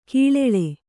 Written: ಕಳ್ಳಾಳ್

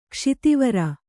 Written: ಕ್ಷಿತಿವರ

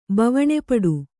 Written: ಬವಣೆಪಡು

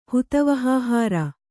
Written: ಹುತವಹಾಹಾರ

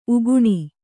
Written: ಉಗುನಿ

